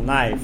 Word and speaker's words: knife: Да, я знаю, что кей не произносится. Knife.